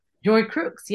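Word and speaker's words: Joy Crookes: But yeah, I like that idea of Joy Crooks, did you say her name is? – Joy Crooks, yes, Crookes.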